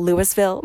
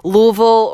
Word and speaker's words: Louisville: It's spelled Louisville, but everyone says Louisville, or like the way that I think about it is just mush all of the letters together and don't move your tongue. Louisville.